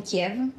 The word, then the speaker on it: Kiev: Is the one in Kiev. Kiev. So Kiev. – I am saying it right, okay.